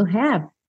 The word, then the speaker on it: have: Já aqui no começo, ó, repara que o E do have tá pintadinho de cinza, logo ele não vai ser pronunciado. O que, que vai acontecer? Você vai juntar o V do have na próxima palavra, have yourself, ok? Have yourself a merry little Christmas.